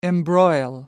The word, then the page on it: embroil: American English